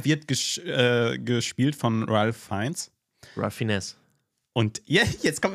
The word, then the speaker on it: Ralph Fiennes: Den spricht man doch nicht Ralph Fiennes aus. Hat mich über die lustig gemacht. Turns out, äh, Ralph Fiennes heißt der Mann nicht. Der heißt Ralph Fiennes.